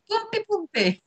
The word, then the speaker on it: Pumpipumpe: Alors ça s'écrit P-U-M-P-I-P-U-M-P-E. Vous me la faites, vous répétez après moi. Boompi Boompi.